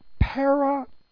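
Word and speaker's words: Parakletos (παράκλητος): So anyway, the word translated helper, comforter, counselor is the word parakletos. Please let me spell that for you. P-A-R-A-K-L-long-E-T-O-S, long S. Parakletos.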